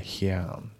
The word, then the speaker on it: here and: であと発音ですけど、 here and のところが輪郭で here and ですね、 here and じゃなくて here and、あと and の D が抜きますね、 unfinish and finish じゃなくて unfinish ですね。